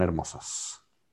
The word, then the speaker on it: hermosos: And remember the H is silent, so son hermosos.